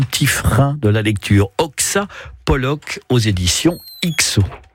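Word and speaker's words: Oksa Pollock: Oxapolloc, alors soyez pas surpris, hein, ça s'écrit O-K-S-A, entre guillemets plus loin, Pollock, P-O-2-L-O-C-K.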